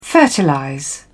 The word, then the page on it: fertilize: British English